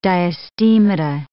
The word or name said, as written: diastemata